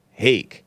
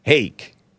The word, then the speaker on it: Hake: Hake, like bake, like cake, like rake, like make, like fake. Hake.